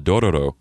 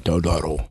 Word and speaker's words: Dororo: There's a show called Dororo that you need to start watching. – Dororo. – Dororo. – How you spell it? – D-O-R-O-R-O.